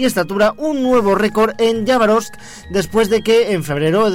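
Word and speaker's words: Yabarovsk (Хабаровск): Una mujer dio a luz en la ciudad de Yabarovsk, que esa es la pronunciación correcta en ruso soviético,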